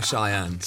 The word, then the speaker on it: Cheyenne: let me spell that for you. It's C-H-E-Y-E-N-N-E. Cheyenne.